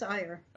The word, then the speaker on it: Iyer: It is Iyer. – Okay, yes. I wasn't sure if it was like air, like even though it's spelt with an I, pronounced totally differently.